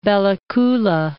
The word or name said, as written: Bella Coola